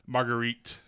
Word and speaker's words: Marguerite: Okay, and the second one I have is from Margaret Bennett. I'm sorry if I'm mispronouncing that. This is from Aftershock, which Aftershock's... – Marguerite Bennett is what producer Kate's telling me.